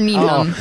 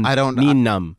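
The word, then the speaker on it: Nunb: Because I've all called him Nine-Numb. – I think it's Nine-Numb. It's I always thought it was numb, as in comfortably Nine-Numb, but it's N-U-N-B, so it's like the B is silent.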